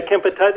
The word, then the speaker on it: Kempeitai: You write about what happened to your father during World War II while he was imprisoned by the Japanese Kempeitai. Is that how you pronounce it? – Yeah, Kempeitai.